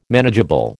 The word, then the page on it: manageable: American English